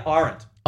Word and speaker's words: Arendt: And on that, she refers to, and I don't know how to pronounce this person, Arendt? – Yeah, Arendt. – Arendt. – Hannah Arendt, yeah.